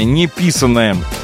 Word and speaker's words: неписанное: Продолжаем наше утро бодрое, душевное, человеческое, неписанное. Правильно ударение поставил.